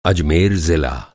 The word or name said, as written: अजमेर